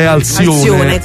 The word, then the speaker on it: Alcione: E mo canto io, si chiama Alcione, si scrive. Ma la pronuncia è Alcione.